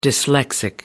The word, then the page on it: dyslexic: American English